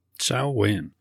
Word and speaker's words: Samhain: Samhain. So to note, Samhain is the proper way to say it. Yet it is most often pronounced as it is spelled Samhain.